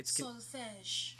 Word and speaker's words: solfège: It's solfège.